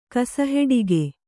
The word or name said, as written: ಕಸಹೆಡಿಗೆ